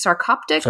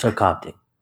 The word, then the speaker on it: sarcoptic: Sarcoptic. – Sarcoptic.